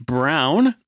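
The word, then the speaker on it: Browne: I am Mike Brown, Brown with an E, in case you're wondering.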